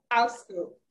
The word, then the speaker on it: AltSchool: Yeah, I said AltSchoolAfrica. Sorry, maybe I didn't pronounce it well. AltSchool. – I had Oscar. AltSchool. – Yeah, AltSchool. Basically, alternative school.